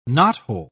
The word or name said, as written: knothole